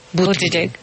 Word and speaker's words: Buttigieg: Se espera que en la jornada de hoy los grandes ganadores sean Bernie Sanders y el exalcalde, ¿cómo se pronunciará el apellido de este exalcalde? – Bloomberg. – No, Buttigieg.